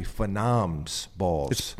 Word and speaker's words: phenoms: I said phenom balls, and it's actually phenoms balls. – It's plural. – Plural phenoms, plural balls. Double plural.